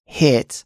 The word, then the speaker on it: HIIT: So when we spell it, although it's pronounced HIIT, the spelling is actually H-I-I-T with two I's instead of one.